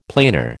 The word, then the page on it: planar: American English